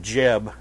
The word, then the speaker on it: Geb: You'll see during the third plague of lice from the dust, that's a repudiation of Geb, G-E-B. I'd rather call him Jeb. I just like that idea. But anyway, Geb is how I believe it's supposed to be pronounced.